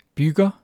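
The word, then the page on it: bygger: Danish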